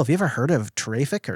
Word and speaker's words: Traefik: He asked if either of us have ever tried out Traffic, which I think is how you pronounce it. It's spelled T-R-A-E-F-I-K.